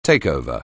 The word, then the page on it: takeover: anglais